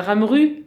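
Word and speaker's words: Ramerupt: Et Ramrout aussi. – Pardon. – Et Ramrout. – Oui, Ramrout, évidemment. Ramru. Enfin, nous, on prononce Ramru chez nous. Mais on peut le prononcer comme on veut.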